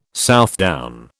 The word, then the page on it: Southdown: British English